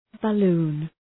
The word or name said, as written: balloon